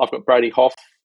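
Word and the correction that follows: Hough: Huff. I've got Brady Huff on my bench.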